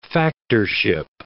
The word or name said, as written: factorship